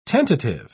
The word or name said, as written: tentative